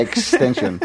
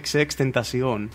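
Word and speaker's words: XXXTentacion: Ή ο άλλος που σκοτώσαν το καλοκαίρι, ο XXX Tentacion, ας πούμε, αντίστοιχα ένας πιτσιρικάς. – Θες να μου ξαναπείς πώς προφέρεται αυτό, γιατί το διάβαζα απλά extension. Πώς το διάβαζα. – XXX Tentacion.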